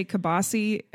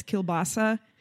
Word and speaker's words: kielbasa: Kind of like how we say kibasi and it's kielbasa.